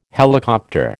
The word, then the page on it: helicopter: American English